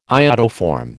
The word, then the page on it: iodoform: American English